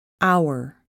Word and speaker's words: hour: The letter H is not pronounced at the beginning of many words. Hour, honest, honor.